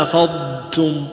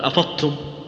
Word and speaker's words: أفضتم: قل فإذا أفضتم أفضتم تبينها أفضتم ولا يصل الحد إلى السكوت قل أفضتم لا لكن لا تضغمها لا تقول أفضتم